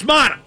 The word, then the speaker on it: Matta: Is it Matta? Matta? It's Matta. Thad Matta just isn't coming to Pitt.